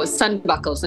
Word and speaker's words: Sandbekkel: So Sandbekkels, and it's spelled S-A-N-D, which sounds like sand. And then B-U-K-K-E-L, buckle.